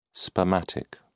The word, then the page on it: spermatic: British English